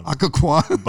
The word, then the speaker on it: Occoquan: Has been the pastor of Alcohun. Did I say it right? – Occoquan Bible Church.